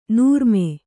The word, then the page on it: ನರಮು: naramu